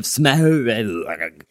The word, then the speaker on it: Smaug: Hobbit, The Desolation of Smaug.